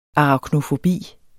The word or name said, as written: araknofobi